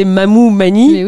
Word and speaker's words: Mamou-Mani: En fait, mon nom c'est Mamou Mani. oui. Et, donc souvent les gens, c'était trop compliqué pour eux. – Pourtant c'est aussi simple, ça s'écrit comme ça se prononce.